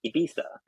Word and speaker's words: Ibiza: I'm in the Baleares Islands, which before I was like, oh yeah, I'm in the Balearics, going to Ibiza. I've been told that's not how you pronounce it. It's Ibiza and the Baleares Islands.